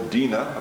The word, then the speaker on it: Dinah: And then Jacob, his 12 sons and one daughter, Dinah, or Dinah apparently, as it's properly pronounced,